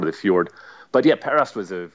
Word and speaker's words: Perast: Perast.